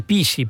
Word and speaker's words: PSSI: Io è segretario fondatore Deus in Macchina del PISI, Partito Super Sovranista no. Italiano. PSSI si pronuncia PISI.